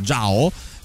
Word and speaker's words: Zhao: Sì, devo dire che è difficile spesso, però alle volte mettendoci un minimo di impegno, cioè se tu sai che il cognome ZHAO si pronuncia Giao,